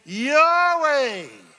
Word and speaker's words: Yahweh: How do you say God Almighty in Hebrew? You say Yahweh.